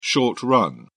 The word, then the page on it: short-run: British English